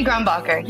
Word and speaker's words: Grombacher: I hope that I pronounced the surname very well, by the way. – Gigi Grombacher, you got it.